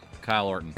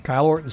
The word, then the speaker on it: Kyle Orton: Rhymes with Bile Borton. – Oh. Kyle Orton. – Kyle Orton.